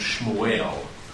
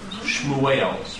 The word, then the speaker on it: Shmuel: The name Samuel in Hebrew, pronounced Shmuel, means name of God. – Oh, interesting. – So, pronounced how? – Shmuel.